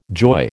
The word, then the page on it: joy: American English